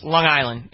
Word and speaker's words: Long Island: Well, see, people on Long Island don't actually say it like that. It's almost one word. No. It's Long Island. It's there is no G.